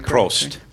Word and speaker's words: Prost: There's Prost in the... Am I saying that right? – Prost.